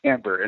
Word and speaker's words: timbre: By the way, that's the way that you pronounce that word. Some people, like on the talent shows, pronounce it as timbre, but it's actually timbre, and it means tone color.